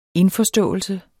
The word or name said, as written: indforståelse